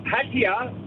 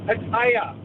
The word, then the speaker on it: Pattaya: The Aussies call it Pattaya, but it's actually pronounced Pattaya.